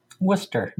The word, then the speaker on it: Worcester: Well, and Leominster or Leominster is right outside of the big city in Central Massachusetts, which is pronounced Worcester. – Worcester, which is what it would have pronounced over here.